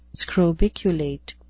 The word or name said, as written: scrobiculate